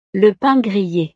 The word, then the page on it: le pain grille: frances